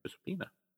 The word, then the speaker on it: Giuseppina: Looks like it's supposed to be Giuseppe, but Giuseppina. – Giuseppina.